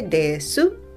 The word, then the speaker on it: desu: Se escribe desu y se pronuncia normalmente des.